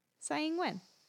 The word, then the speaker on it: Tsai Ing-wen: You're basically right. It's like, but there's more of like it's a Tsai like. – Okay. – Now I'm gonna butcher it. Tsai Ing-wen. – Tsai Ing-wen.